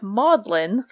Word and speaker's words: Magdalen: Um, it actually, though, is not pronounced the way that we think it is. It is, in fact, pronounced kind of like Maudlin. Maybe you all know this if you're some of our uh, listeners in the UK. You probably know this, but it's pronounced as Maudlin because during the time of its founding, in like, what is it, 1458, Magdalene mm.